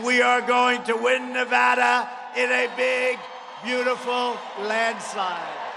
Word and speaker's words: Nevada: I have a beautiful building right down the road with the proud, hardworking patriots of Nevada. You got to say Nevada. You say that wrong, you got problems. You say Nevada, they go, what the hell? Gotta be Nevada.